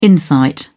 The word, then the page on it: insight: British English